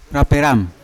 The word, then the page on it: raperam: Yawa